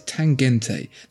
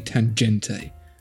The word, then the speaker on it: Tangente: They say it's Tangente. That's definitely a hard G. That's definitely Tangente.